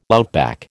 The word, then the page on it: outback: American English